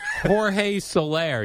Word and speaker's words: Jorge Soler: I got both names incorrect. I pronounced both names incorrect. – George Soler. – Jorge Soler, you say.